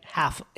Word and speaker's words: half: He said it correctly, but you Yeah. The ear, so, like half. It's hard to hear the L.